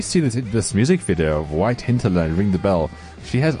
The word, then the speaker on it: White Hinterland: See, within 2 minutes, you've already got a name wrong. It's White Hinterland.